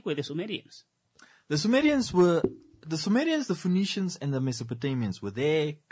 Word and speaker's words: Phoenicians: It's literally spelled like Phoenix, but Phoenicians.